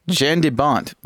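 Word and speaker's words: Jan de Bont: The original director was director Jean de Benet. Wait, I said that wrong. Jean de Bont. Wow, murdered his name.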